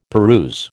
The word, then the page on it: peruse: American English